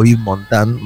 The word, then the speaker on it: Yves Montand: tuvo amores también tumultuosos con artistas más jóvenes, el caso de Yves Montand, gran crooner marsellés, que también deben conocer, o Yves Montand, mejor pronunciado,